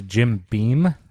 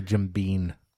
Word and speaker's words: Jim Beam: I know it's Jim Beam, but I always say Jim Bean.